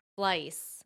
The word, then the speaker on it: Fleiss: Fleiss.